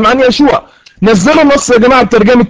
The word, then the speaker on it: يشوع: اذا يشوع ايضا اسمه يسوع، والنطق برضو لا اشكال، يسوع زي ما قلنا تنطق في العبريه يشوع.